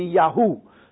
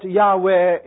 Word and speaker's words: Yahweh: There's two Dutch scholars who have written adamantly that it should be Yahoo. So you can see that we don't know exactly the pronunciation. But I accept, from my academic background, that Yahweh is the commonly accepted pronunciation of the tetragrammaton, the four letters of God.